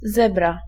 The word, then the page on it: zèbre: [zɛbʁ]; France